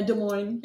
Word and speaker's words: Des Moines: Yeah, Des Moines. Yep. The S is silent.